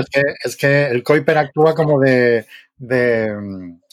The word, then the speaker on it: Keuper: Kuiper. Hay gente que dice Keuper. Lo lo dije mal. – No hice mal, vale, así lo aprendo. Es que yo iba a decir Kuiper, pero digo, algún profe decía Keuper y digo, no sé cómo va a ser. – A ver, si tú dices Keuper, la gente lo escribe bien. Si dices Kuiper, hasta que te enteras que se escribe Keuper, pueden pasar dos años.